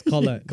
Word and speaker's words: Collette: Yes, Collette.